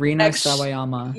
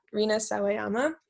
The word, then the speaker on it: Rina Sawayama: I don't know if Rina Sawayama. – Yeah, Rina Sawayama.